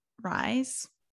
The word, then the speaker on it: Rhys: but Jean Rhys.